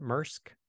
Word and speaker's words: MRSK: And as an alternative, they're offering, I guess you pronounce it MRSK, M-R-S-K.